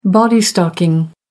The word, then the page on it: body stocking: American English